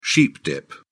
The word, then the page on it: sheep dip: British English